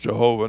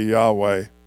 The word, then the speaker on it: Yahweh: They used to tell us it should be pronounced Jehovah. Now most of the scholars say Yahweh.